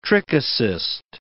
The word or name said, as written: trichocyst